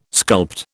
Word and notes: sculpt: British English

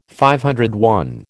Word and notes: DI: American English

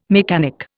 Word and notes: mechanic: Indian

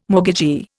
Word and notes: mortgagee: Indian

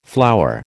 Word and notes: flower: American English

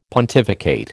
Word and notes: pontificate: American English

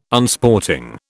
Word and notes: Unsporting: British English

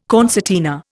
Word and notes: concertina: Indian

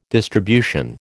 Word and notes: distribution: American English

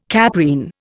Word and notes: caprine: Indian

